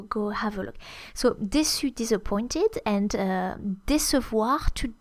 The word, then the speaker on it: déçu: So we use déçu, which is spelled D-E acute, C with a French cédille and U. And an E if you are referring to a female who is disappointed, déçu.